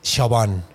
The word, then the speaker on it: Siobhan: Siobhan se escribe, se dice Siobhan.